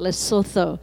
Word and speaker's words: Lesotho: And so we prayed for this couple in Maseru, Lesotho, or you can pronounce it Lesotho.